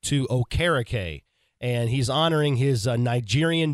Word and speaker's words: Okereke: and so now it's Okereke, and so that's what we're going to go with from here on out to honor Bobby Okereke and, quite frankly, get his last name pronounced correctly the way he wants it to be.